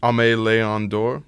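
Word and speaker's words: Aimé Leon Dore: Ame Leon Dor.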